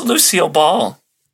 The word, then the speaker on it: Lucille Ball: Is it O'Ball or is it just Ball? – No, it's just Lucille Ball.